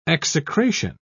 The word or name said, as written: execration